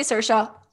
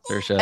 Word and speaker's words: Saoirse: I was going to say Saoirse. – Saoirse.